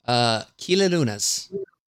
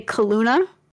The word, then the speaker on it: Kilaluna: So I was going to say Kaluna, like Kalua. How are you saying it? – Kilaluna. – Kila Luna. – I mean, exactly how it's spelled, right? – Freaking time.